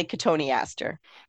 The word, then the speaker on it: Cotoneaster: something that's mispronounced because people sound it out and it's spelled like cotton Easter, but it's really Cotoneaster.